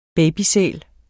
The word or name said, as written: babysæl